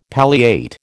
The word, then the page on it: palliate: American English